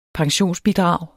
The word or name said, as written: pensionsbidrag